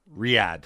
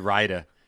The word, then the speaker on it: Riyadh: Riyadh. I said Raida.